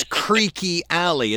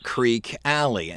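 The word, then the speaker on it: Creeque Alley: It is Creaky Alley. It's not Creek Alley.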